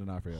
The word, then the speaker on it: D'Onofrio: Is it Vincent D'Onofrio? Is that how you say it? – Yeah, Vincent D'Onofrio.